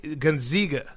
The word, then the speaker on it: Gonzaga: It's a long A. – Zaga. – Gonzaga.